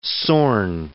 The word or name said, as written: Zorn